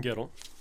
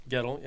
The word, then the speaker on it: Guettel: Music and lyrics by Adam, how do we say his name? Any idea? – Adam Gettle. – Gettle? – Gettle, yeah. – G-U-E-T-T-E-L.